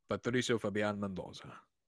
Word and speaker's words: Patricio Fabian Mendoza: Patricio Fabian Mendoza. – Patricio Fabian Mendoza. I'm not saying it right. I'm adding, like, a weird Italian twist.